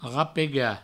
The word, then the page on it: rapegar: provençal; rapega